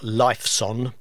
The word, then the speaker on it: Lifeson: It's spelt Lifeson, but pronounced Lifeson.